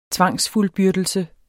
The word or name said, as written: tvangsfuldbyrdelse